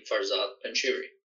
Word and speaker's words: Farzad Panjshiri: Farzad Panjshiri.